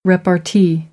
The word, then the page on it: repartee: American English; ɑːr